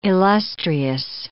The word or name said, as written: illustrious